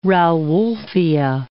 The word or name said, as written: rauwolfia